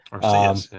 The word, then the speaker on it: Arceus: Arceus, yeah.